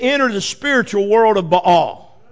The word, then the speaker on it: Baal: We sometimes pronounce it Baal, but in Hebrew it's pronounced Baal.